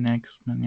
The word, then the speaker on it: Nagelsmann: That Nagelsmann, is that how you say his name? – Yeah, Julian Nagelsmann, yeah. – Yeah, Nagelsmann.